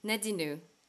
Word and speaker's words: Nadinou: How do you say that? Nadino. – Nadinu. – Is that the French way? – My French nickname.